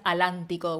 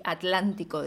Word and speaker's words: atlántico: Entonces, bueno, no digas sentate, no sé cuáles son los ejemplos de bello, no hables guaraní, eh, pronuncia los grupos cultos, no digas alántico o el aleti, de sí atlántico, de No sé, porque esa como es de ellos no la prohíben tanto, ¿no? – Ah, esa como es española.